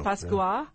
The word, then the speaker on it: Pascua: Pascua.